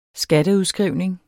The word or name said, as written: Skatteudskrivning